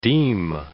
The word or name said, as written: deem